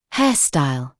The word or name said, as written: hairstyle